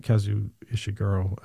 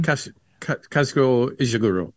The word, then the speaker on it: Kazuo Ishiguro: And it's an adaptation of Kazuo Ishiguro's movie, I think, or sorry, not Kazuo Ishiguro, I'm totally butchering that. But anyway, it's an adaptation. – Kazuo Ishiguro.